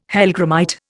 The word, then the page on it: hellgrammite: Indian